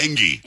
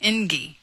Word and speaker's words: Engie: It's E-N-G-E-E. How would you pronounce that? – I would say NG, but I've said that twice now. – Right. – Both times you corrected me. It's Engie.